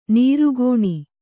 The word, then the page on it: ನಿರ್ಗುಣ: kannada